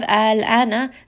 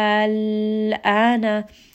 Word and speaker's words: الآن: Now when we have الآن, now you will listen that there is no emphasis on the lam. Instead, I will simply rest on this lam. الآن.